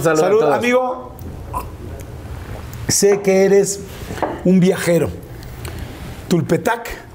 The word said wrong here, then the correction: Tulpetlac: Tulpetlac en mi lugar de nacimiento. La pronunciación, por favor. Tulpetlac. Tulpetlac. Exacto.